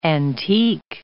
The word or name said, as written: antique